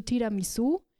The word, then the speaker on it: Tiramisu: Zu Tiramisu. Und ja, ganz wichtig, hier muss die Betonung auf dem U liegen.